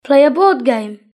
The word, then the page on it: play a board game: British English